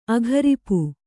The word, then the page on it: ಅಗರಿಪು: agaripu